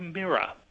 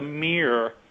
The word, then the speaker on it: mirror: And we see it also, for example, in a word like mirror, for example, where the tendency to split it into two syllables is actually, in fact, a slightly later adaption, whereas, in fact, in the 18th century, the mirror, which is more like the kind of 18th century pronunciation, which is the standard American pronunciation.